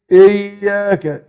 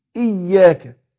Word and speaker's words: إياك: ناخد بالنا كتير كتير نجي يقرأوا الهمزة المكسورة المبدوء بها كده، ينطق زي حرف الايه بالانجليزي بالانجليش، فيقول ايه؟ اياك ايه، اياك نعبد واياك وايه. لا خطأ، انا اقول اي مش ايه، اي، فانا حقق الكسر، فهنطق الهمزة المكسورة المبدوء بها اي زي حرف الاي، مش زي حرف الاي، لا زي حرف الاي، اقول اي اياك. وبعدين الياء بعدها مشدده، ما ينفعش ان انا انطقها من غير تشديد، لان هيتغير المعنى، معنى الايه.